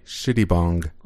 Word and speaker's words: Shittibong: B-O-N-G. – I'll spell it. S-H-I-T-T-I-B-O-N-G. Shitty bong.